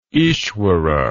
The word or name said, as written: Ishvara